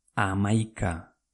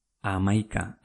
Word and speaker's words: hamaika: H-O-G-E-I. Ogei. Venga, ¿cómo se escribe 11 en euskera? Amaika. Amaika. H-A-M-A-I-K-A. ¿Qué tal vas? Venga, seguimos. Diecisiete. Diecisiete. ¿Cómo se escribe? Amasaspi. H-A-M-A-Z-A-Z-P-I.